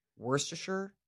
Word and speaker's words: Worcestershire: How do you say it? – Worcestershire. – I think that sounds right. I think that sounds Worcestershire. – Right. Worcestershire. – It's not Worcester. I'll tell you that.